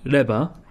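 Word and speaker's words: leba (レバー): You do actually say, you can say leba.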